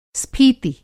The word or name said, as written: स्फीति